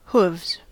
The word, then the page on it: hooves: American English